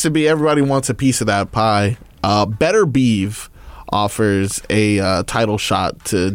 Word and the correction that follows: Artur Beterbiev: Not how you say his name. – Artur Beterbiev? – Artur Beterbiev.